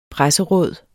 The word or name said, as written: presseråd